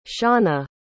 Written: seana

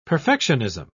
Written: perfectionism

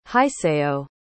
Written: hisayo

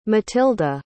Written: matilde

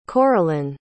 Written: coralyn

